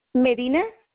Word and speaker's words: Medina: R-O-C-I-O? – R-O-C-I-O. – Okay, your first name. I mean, your last name? – Medina. – Spell that. – M-E-D-I-N-A.